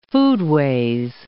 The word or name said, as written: foodways